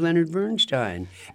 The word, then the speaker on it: Leonard Bernstein: My impression, I thought he was Leonard Bernstein. And by the way, we're saying it right.